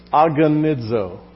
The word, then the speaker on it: agonizo: The Greek word that's translated here, striving, I would pronounce this way, agonizo. What does that word sound like? Agony, there you go.